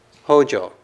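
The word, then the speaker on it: Hōjō: So in Japanese, for example, Fangjang is pronounced Hojo.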